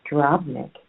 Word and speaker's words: Drobnik: My last name, Drobnik, is my married name. It is pronounced drob, like you're going to draw something, but then add a D. Drob.